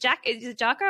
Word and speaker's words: Jocko: Which I know in the world of Jocko, is it Jocko? Am I saying it right? – Yeah.